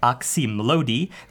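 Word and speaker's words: Oczy Mlody: Actually, phonetically, it looks like oxymlody. There's even a video of Wayne Coyne pronouncing it exactly like that as he explains in a somewhat incoherent fashion how he came up with the title. It was inspired by the uh, Polish for eyes of the young or young eyes.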